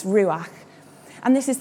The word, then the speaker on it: ruach: The Hebrew word for the Holy Spirit or God's personal presence is ruach. And you've got to get a nice sort of back sound at the end of that word, which I love as a speech and language therapist.